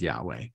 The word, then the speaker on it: Yahweh: And then God reveals the second name, which scholars pronounce as Yahweh, but literally it's four consonants without any vowels.